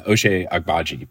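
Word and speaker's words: Ochai Agbaji: Is it O'Shea Agbaji? – Oh, yeah. O'Shea Agbaji.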